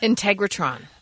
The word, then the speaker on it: Integratron: Integratron. – That sounds better. That sounds more like how you would pronounce it.